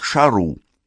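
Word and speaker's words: šarru: Слово «царь» на аккадском языке произносится как «шару».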